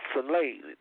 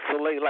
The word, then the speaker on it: Soleil: But Soleil, that's the way you pronounce it. Soleil, like you slay somebody.